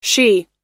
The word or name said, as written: shea